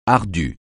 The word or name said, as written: ardu